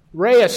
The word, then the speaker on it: Rai: On the Polynesian island of Palau, and I may not be pronouncing this right, Reis stones, spelled R-A-I, became currency.